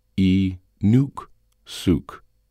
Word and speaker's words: Inuksuk: This is an Inuksuk, pronounced E-nuk-suk.